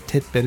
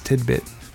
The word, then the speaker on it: tidbit: Tidbit. It's not titbit, it's tidbit.